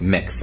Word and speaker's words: Mx: So for pronunciation, MX is pronounced like mix, like you're mixing things together.